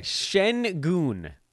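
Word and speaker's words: Şengün: You want to tell me how to pronounce Alperen Sengun's name correctly, so we can just prove that I've watched the guy play. – Sengun. – Sengun.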